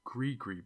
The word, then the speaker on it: gris-gris: It said it was called Gree-Gree Bag. But I prefer calling it a gris-gris bag, even if it's pronounced Gree-Gree.